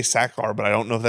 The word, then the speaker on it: Sachar: Let's see. Oh, you're right. Sachar.